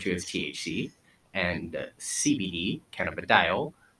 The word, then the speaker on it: cannabidiol: Cannabidiol. – Yeah. – That's cannabidiol.